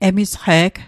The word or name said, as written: emisqe'g